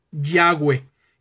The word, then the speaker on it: Yahweh: Y si nosotros queremos pronunciar Jehová, está bien, no pasa nada. Pero si queremos bajo el entendimiento de lo que estamos hablando, utilizar la pronunciación más correcta, bueno, la más correcta pues es Yahweh, que es la que voy a emplear en este estudio.